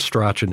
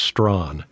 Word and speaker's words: Strachan: He says, it's not Strachan, it's Strawn. You're mispronouncing the guy's name.